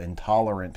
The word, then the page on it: intolerant: American English